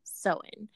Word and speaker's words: Samhain: And on today's episode, how appropriate for our very first, we'll be talking about Samhain or Halloween, or also more traditionally pronounced Sow-een.